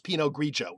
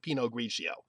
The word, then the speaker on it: Pinot Grigio: But Pinot Grigio is correctly pronounced Pinot Grigio, not Pinot Grigio.